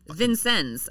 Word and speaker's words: Vincennes: Why does every town have to have Vincennes, I would say. – Vincennes? What is it? You think you're in France? – Bonjour. – Frickin' Indiana. A trooper patrolling in Vincennes, Indiana, around 9 p.m.